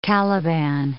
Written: Caliban

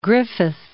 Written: Griffith

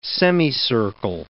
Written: semicircle

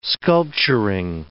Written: sculpturing

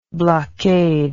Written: blockader

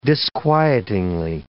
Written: disquietingly

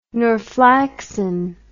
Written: norflaxin

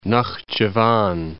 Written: Naxcivan

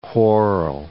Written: whorl

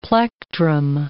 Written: plectrum

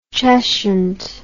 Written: Cheshunt

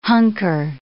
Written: hunker

